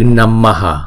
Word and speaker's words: namaha: Chữ đầu tiên là chữ Namaha, mà chúng ta chỉ đọc cái chữ H này, không đọc là hà mà đọc là H. Chúng ta chỉ hà cái hơi ra thôi.